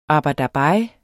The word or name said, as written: ABER